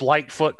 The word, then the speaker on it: Lightfoot: It's Lightfoot Clan. – There you go. – And light it's spelled is in out. Light. Yeah, light is in light switch, and then foot is in foot, and then clan.